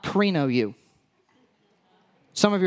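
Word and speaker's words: krino (κρίνω): Now, the word that he uses here is pronounced krino. It's a Greek word.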